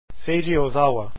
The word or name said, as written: ozawa